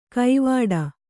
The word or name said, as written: ಕೈವಾಡ